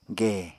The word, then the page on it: gè: apatani